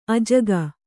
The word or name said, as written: ಅಜ್ಜುಗ